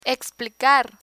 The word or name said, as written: explicar